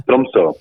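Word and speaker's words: Tromsø: Sí, yo estuve el año pasado en, Oslo y en, creo que se dice Tromso, ¿no? – Tromso. Tromso. – Trumso. Mm. la pronunciación todavía no la llevo bien.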